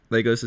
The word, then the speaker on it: Lagos: Lagos is how you say it? – Yeah. – Okay.